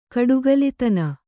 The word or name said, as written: ಕಡುಗಲಿತನ